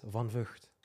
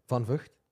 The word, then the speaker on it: Van Vucht: Van Vucht. – Van Vucht. – It's not too bad. But you guys are saying the F word all the time when you're trying to pronounce it.